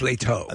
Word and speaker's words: Leto: Jared Leto. Or Leto, I think he pronounces it as. – It's Leto. – Leto.